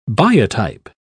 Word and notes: biotype: British English

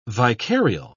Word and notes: vicarial: British English

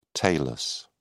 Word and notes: talus: British English

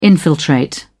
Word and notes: infiltrate: British English

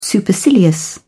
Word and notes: supercilious: British English

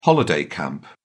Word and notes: holiday camp: British English

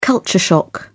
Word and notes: culture shock: British English

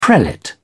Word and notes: prelate: British English

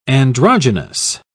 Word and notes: androgenous: British English

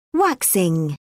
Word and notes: waxing: British English

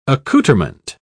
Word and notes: accoutrement: British English

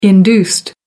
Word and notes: induced: American English

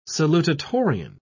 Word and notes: salutatorian: British English

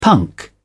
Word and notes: punk: British English

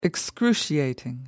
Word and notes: excruciating: American English

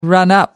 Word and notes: run up: American English

